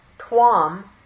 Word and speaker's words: tvam: Tvam, with a long A, is the accusative singular.